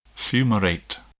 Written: fumarate

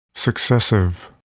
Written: successive

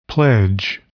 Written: pledge

